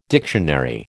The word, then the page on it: dictionary: American English